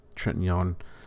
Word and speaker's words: Trintignant: Trintignant.